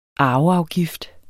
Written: arveafgift

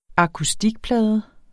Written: akustikplade

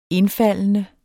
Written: indfaldende

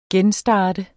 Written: genstart

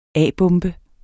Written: a-bombe

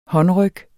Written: håndryg

